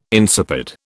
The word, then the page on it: insipid: British English